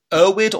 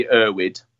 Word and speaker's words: Erwood: And you, the name of your jester is it Erwood, or how do you pronounce that? – Yeah, so for an English-speaking audience, it'd be Erwood.